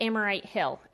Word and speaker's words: Amorite Hill: They finally made it to Amorite Hill. Am I saying that right, Ronnie? – That sounds right, yeah.